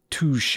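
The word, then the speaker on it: touché: touche. – For the listeners at home, this is spelled T-O-O space S-H-A-Y.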